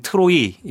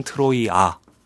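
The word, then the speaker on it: 트로이아가 (Τροία): 일단 많이 알려진 트로이 이거는 영어식 발음이고요. – 네. 네. – 그거를 그리스 원어로 보게 되면 트로이아가 맞는군요.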